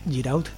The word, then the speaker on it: Giraud: No sé cómo se dice en francés, ¿se escribe Giraud? – Sí, está bien. – Muy bien, ese acento te ha quedado muy bien.